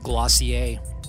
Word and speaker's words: Glossier: I just found out that Glossier is actually pronounced Glossier.